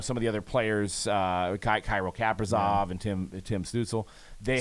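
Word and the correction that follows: Stützle: Stutzla. – Have whatever. I knew you were gonna correct me on that one. But, how do you say it? – Stutzla.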